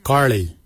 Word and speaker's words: giilyi: Dab is spelled D-H-A-A-B. It is a traditional knife, a dab. For boomerang, we say karli. Karli is the traditional name for boomerang. Karli is spelled G-I-I-L-Y-I, karli.